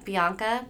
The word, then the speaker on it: Bianca: C-O-L-L-I-E-R dot Bianca, B-I-A-N-C-A.